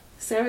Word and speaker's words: Cera: But I thought all the characters were really great, all the actors were very good, and I thought that, like, Michael Cera... Sarah, is that how you pronounce it? – Yeah, yeah.